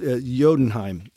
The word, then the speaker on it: Jotunheim: Also, Jotunheim is the way you pronounce.